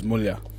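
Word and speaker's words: mulha: our local Ingrata word is mulia.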